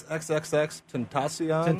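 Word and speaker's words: XXXTentacion: I think it's XXX Tentacion.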